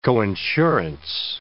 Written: coinsurance

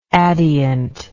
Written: adient

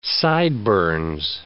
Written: sideburns

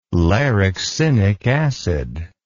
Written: larixinic acid

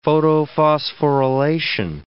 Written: photophosphorylation

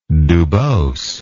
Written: dubos

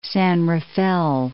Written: San Rafael

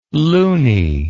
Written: luny

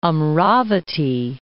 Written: Amravati

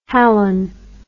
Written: halon

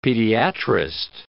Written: pediatrist

